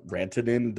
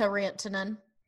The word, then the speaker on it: Rantanen: Certain people like to add a little accent to Rantanen. – Yeah. Rantanen. Yeah. – Rantanen. – Who is that announcer on NBC that always says Rantanen? He pronounces no other names in their native tongue, but it's always Rantanen.